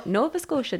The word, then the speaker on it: Nova Scotia: I, I think it's Nova Scotia. Oh yeah, yeah, you're Canadian. That's Nova yeah, Scotia, right? Okay. No, Nova Scotia.